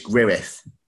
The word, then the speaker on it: Gwrych: So if my Welsh pronunciation and my use of the International Phonetic Alphabet is right, it's Gwrych.